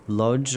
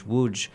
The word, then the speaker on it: Łódź: There's one particular, very well known case of the city of Lodz, or it's pronounced in Polish, Łódź, where there was uh, a very large ghetto, and that ghetto survived in some fashion until 1944.